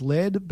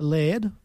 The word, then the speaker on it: Laird: So you got Laura Benatti, who plays Alison Becker, and Matthew Broderick, who plays Laird Becker. Laird. – Laird. – Laird. L-A-I-R-D.